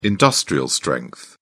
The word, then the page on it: industrial-strength: British English